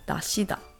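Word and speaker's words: dashi (出汁): But that's what it says on the brand here dashida. In Korean, this is called tashita. It's a little different from the Japanese pronunciation because Japanese is just dashi without the da, extra da at the end.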